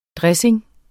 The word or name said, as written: DRESSINGENS